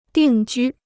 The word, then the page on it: 定居: dìng jū